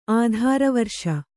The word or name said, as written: ಆಧಾರವರ್ಷ